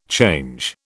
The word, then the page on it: change: British English